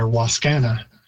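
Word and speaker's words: Wascana: Again, that's another one where Waskana, we've got a very English stress pattern on it, putting it on the second syllable, whereas in Cree it would normally be on the first, a little more emphasis, a higher pitch, and Oskana for bones.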